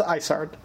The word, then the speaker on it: Isard: yeah, I think they made a joke of calling her Iceheart, and phonetically that sounds like Isard. – Oh. – So I think you're right. I think it's Isard.